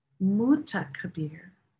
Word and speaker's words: Muta Kabir: So it's Kabir, but it's also M-U-T-A. And then it's K-A-B-B-R. So there's a little bit of emphasis on the ta and the Kabir. So there's two little points of accentuation.